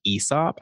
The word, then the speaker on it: Aesop: You actually pronounce it Aesop, and I only learned that because a friend worked there. But no one says Aesop.